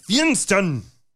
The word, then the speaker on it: Feinstein: Feinstein. If you're going to say it, you got to say it right with the proper accent. Feinstein. – The right emphasis on the right syllables.